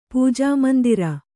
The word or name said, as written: ಪೂಜಾಮಂದಿರ